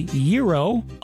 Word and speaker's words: gyro: How do you say the word spelled G-Y-R-O? Some people say gyro, others say gyro.